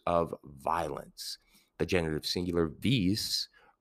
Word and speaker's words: vis: There is um, one third declension istem noun worth talking about and taking a visit. It's vis vis.